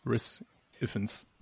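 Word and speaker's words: Rhys Ifans: Ja, ich sage immer Riz, aber ich glaube, es ist falsch. Riz. Ah, super, danke. Ja, dann, dann einigen wir uns auf Riss, Riz Iffens oder so.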